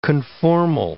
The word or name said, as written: conformal